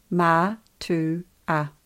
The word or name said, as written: matua